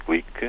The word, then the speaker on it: Glück: Luísa Glick, uh, eh, pronuncia-se assim porque o apelido é de origem húngara,